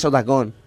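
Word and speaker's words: Otacon: No, has dicho que antes que Otacone era... – Cállate. – Y es al revés, es tal, es Otacón.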